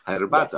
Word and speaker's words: herbata: So herb is herbata. Is silent in English, but in Polish, you hear PH, herbata.